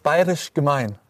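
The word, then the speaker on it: Bayerisch Gmain: Jetzt sind wir hier, Felix, in Bayerisch Gemein.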